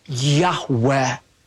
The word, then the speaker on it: Yahweh: feel it's pronounced something like a breathy Yahweh?